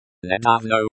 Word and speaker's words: Недавно: Недавно. Недавно, the E at the end of НЕ. НЕ is pronounced as the E in bed.